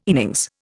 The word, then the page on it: innings: Indian